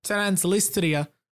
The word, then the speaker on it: Transnistria: This area is called Transnistria.